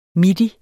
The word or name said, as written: midi